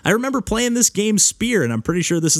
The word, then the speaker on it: Speer: There was actually, I had a recent back and forth with the developer on Twitter of this game called Spear, which is spelled S-P-E-E-R, and it actually has like brackets around it.